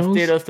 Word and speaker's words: Tostitos: Pop in the Tostinos, Tostitos. How do you say it? Tostinos. – Tostitos, Tostitos, Tostitos.